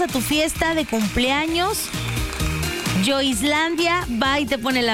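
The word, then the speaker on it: Joislandia: Así que entra a nuestra página de Facebook, Yo Islandia. Así como se escucha J-O-I-S-Landia, así se escribe.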